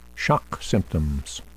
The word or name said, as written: shock symptoms